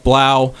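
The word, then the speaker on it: Blough: Yeah, the third string quarterback Jeff Blow, or Jeff Blau, or however you want to say. It. I think yeah, it's supposed to rhyme with cow, so it's Jeff Blau.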